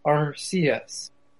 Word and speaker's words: Arceus: He prefers the pronunciation Arceus.